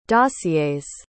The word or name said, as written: dossiers